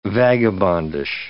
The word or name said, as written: vagabondish